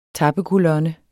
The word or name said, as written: tappekolonne